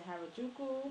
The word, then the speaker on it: Harajuku: And he's like, you know, Japanese culture, as far as, I think he said Harajuku, mm-hmm.